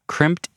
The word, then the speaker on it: Crimpd: Crimped is spelled C-R-I-M-P-D.